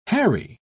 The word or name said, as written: harry